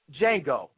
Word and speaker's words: Django: Listen, also, Django, the D is silent, sir.